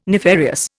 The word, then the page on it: nefarious: Indian